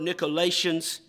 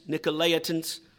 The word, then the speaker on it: Nicolaitans: Some may pronounce that word Nicolaitans, Nicolaitans.